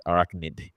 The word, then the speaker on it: arachnid: What does your senses, your arachnic senses, is that how you say it? Arachnic? Arachnid. In Spanish, it would be arachnid.